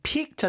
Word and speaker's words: pique: P-E-A-K 그 뭐 네. 휴가 피크철 할 때 피크하고 지금 이거 P-I-Q-U-E 하고 발음은 똑같은데 이 픽